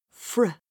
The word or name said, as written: fr